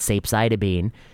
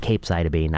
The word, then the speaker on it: capecitabine: And the last thing I'd say is, you know, saw a bunch of people were annoyed that I called it capecitabine. Said I should have used that hard C sound, capecitabine.